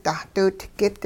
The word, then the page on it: dahdiwidgit: hupa